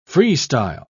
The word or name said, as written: freestyle